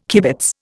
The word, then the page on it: kibitz: Indian